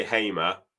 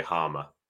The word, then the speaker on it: Hamer: How do you say his surname? We say Hamer. Um, Sky Sports, for some reason, say Harmer. But uh, yeah, we all, we we all say Hamer.